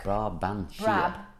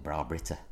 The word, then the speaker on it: Brabantia: Yeah, that's Brabantia. – Is that how you say it? – Look. – Brabantia. – Brabantia. Brabantia. – I've always called it Brabrita.